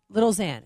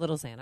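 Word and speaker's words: Lil Xan: It's Lil X-A-N. How do you pronounce that? Lil Zan? Yeah, that's why she's crying. Lil Zan. It's called Lil Zan.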